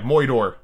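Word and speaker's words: moidore: Yeah, Moidor is how that's pronounced.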